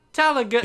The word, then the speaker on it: Tallaght: T-A-L-L-A-G-H-T. – Yeah, Talakt. Talagut. – Yeah, Tala. – Talagut. – Yeah. The GHT is silent.